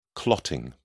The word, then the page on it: clotting: British English